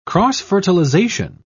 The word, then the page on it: cross-fertilisation: British English